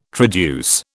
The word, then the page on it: traduce: British English